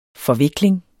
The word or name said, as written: forvikling